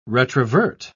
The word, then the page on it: retrovert: British English